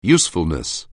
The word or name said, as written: usefulness